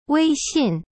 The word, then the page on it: 微信: wei xin